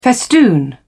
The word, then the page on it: festoon: British English